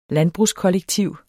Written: landbrugskollektiv

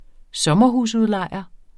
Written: sommerhusudlejer

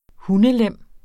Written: hundelem

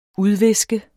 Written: udviske

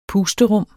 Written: pusterum

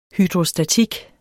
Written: hydrostatik

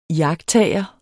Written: iagttager

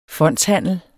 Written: fondshandel

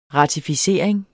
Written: ratificering

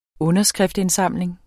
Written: underskriftindsamling

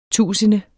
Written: tusinde

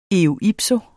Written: eo ipso

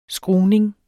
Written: skruning

